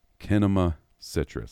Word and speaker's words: Kinema Citrus: You know, maybe like changing the first letter from a C to a K, kind of like corn. All right. But then I watched the behind the scenes feature on this one, and they straight up pronounced it Kinema Citrus. So my bad for getting that wrong for so long.